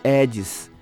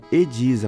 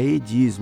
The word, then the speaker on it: Ediz: Meu nome muitas pessoas chamam de Edis, mas meu nome é Ediz, a pronúncia é Ediz, não tem acento. Ediz, Ediz Cruz.